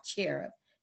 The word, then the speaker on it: Cherub: Because of this child, Cherub. C-H-E-R-U-B.